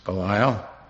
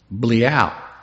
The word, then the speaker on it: Belial: He said, "Nah, you don't pronounce it Belial. It's Bleal." So I don't know. B-L-E-E-A, root of two words.